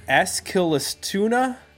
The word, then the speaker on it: Eskilstuna: So Kent formed in, I cannot pronounce this word, Eskilstuna. I don't know. The word tuna is in there, but it's probably not pronounced tuna because it's Swedish.